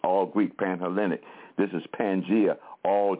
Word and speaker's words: Pangea: and that's spelled P-A-N-G-E-A, Pangea, just like it sounds, Pangea.